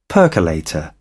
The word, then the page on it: percolator: British English